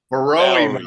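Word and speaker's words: Borowy: Hank Bowery. – Barrowy, Mark.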